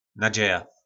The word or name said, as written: Nadzeja